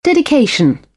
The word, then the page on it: dedication: British English; ɪ